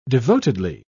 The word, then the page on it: devotedly: British English